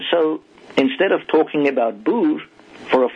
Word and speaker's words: boer: Now, the word for farmer in Dutch is boer, B-O-E-R, boer. But that soft R at the end, they could not pronounce, and you would struggle with it as well. So they, instead of saying boer, they said boulou. They put a lu at the end, because that's more in tune with their language.